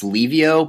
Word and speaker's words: Livio: Like, my name is like, it's pronounced Livio, but some of my best friends say Livio.